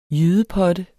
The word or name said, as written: JYDEPOTTES